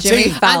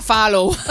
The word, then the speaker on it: Fallon: Lo dije bien. – Jimmy Fallon. – Antes yo decía Fallow.